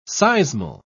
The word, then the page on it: seismal: British English